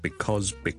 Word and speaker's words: because: Because 呢个字个发音，好多时候咧，我哋系唔会读成好完整嘅，我哋正确啊，because，我哋系 because 系 b 啦，第一个 vowel 系 a 啦，第二个 vowel 系 o，跟住后面系一个 z 音，because。